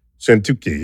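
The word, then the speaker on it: Szentkuti: Sentuki, yeah, okay.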